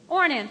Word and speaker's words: Ornan: Ornan, thank you.